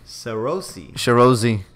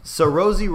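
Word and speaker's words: Saoirse: I don't know how to pronounce this girl's name. Uh-huh. The one from Lady Bird. – Sarosi. – Is that how it is? – Sarosi Ronan. – That sounds a lot better than what I was reading.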